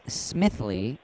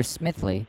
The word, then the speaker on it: Smithly: P-A-T-C-H? – Correct. – And then last name? – The name is Smithley, and I have never met another Smithley. And this is spelled S-M-I-T-H-L-Y. Huh.